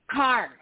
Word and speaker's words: Kaur: Kaur. Okay. So just like the car, like you're driving a car.